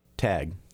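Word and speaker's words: TTAGG: It's T-T-A-G-G, and that's pronounced tag.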